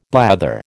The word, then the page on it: blather: American English